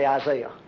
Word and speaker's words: Isaiah: Not Isaiah. That's the way the British say Isaiah.